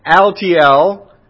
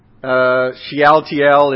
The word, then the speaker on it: Shealtiel: both of these names actually have four syllables in them. So when I think about Zerubbabel, I think about rub-a-dub-dub, three men in a tub. And when I think about Shealtiel, I think about sweet tea.